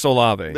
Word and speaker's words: Olave: Chris Olave.